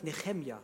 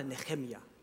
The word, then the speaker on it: Nehemia: Nehemia wird eigentlich Nehemia ausgesprochen. Sag mal Nehemia.